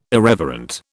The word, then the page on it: irreverent: British English